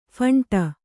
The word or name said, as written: ಫಂಟ